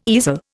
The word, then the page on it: easel: Indian